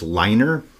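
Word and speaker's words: Leiner: Denny, I think it's pronounced Liner, who died of lung cancer 3 years ago.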